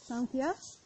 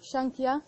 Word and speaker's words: Sankhya: It is written like this, Sankhya, but actually pronounced with an N, so it can be pronounced like Sankhya or Shankhya, depends which place from India you come from.